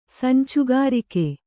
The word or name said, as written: ಸಂಚುಗಾರಿಕೆ